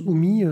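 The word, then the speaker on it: Hum!: Donc ça se prononce Humi, son nom d'artiste, mais ça s'écrit Hum avec un, point d'exclamation.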